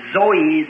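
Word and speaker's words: Zoe (ζωή): And the both Greek words as the life of God or eternal life is pronounced Zoe, Zoe, Zoe life.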